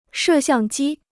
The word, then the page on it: 摄像机: shè xiàng jī